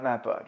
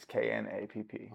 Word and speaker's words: Knapp: No, not Napa. Just Knapp. It's Knapp.